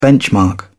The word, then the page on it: benchmark: British English